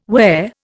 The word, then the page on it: whare: Indian